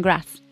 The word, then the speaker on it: Graz: in Graz? – Yeah.